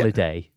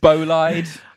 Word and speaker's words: Bolide: Not Bolliday. – Going on your Bollidays. – Anywhere nice on your Bollidays. – Bolide. – Oh, well. – Bolide. That's quite the American pronunciation.